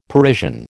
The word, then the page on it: Parisian: American English